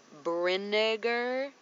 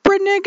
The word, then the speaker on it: Brinegar: It looks like it is pronounced Brinegar. – Whoa, hard R there. – Cabin. B-R-I-N-E-G-A-R.